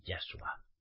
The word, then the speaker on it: Yahshua: No, mira, Miguel, se dice Yeshua, no se dice Yeshua, se dice Yahshua.